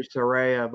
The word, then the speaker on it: Saraya: Uh, it's actually Soraya, but doesn't matter.